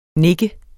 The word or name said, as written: nik